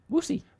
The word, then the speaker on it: Woosey: Was it Woosey? W-O-O-S-E-Y. How'd you say that? – Sorry, say it. – W-O-O-S-E-Y. What'd you say? – Woosie. – Woosie! Matt Woosie!